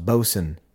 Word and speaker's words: boatswain: It's spelled boatswain, BOATSWAIN, but it's pronounced bosun.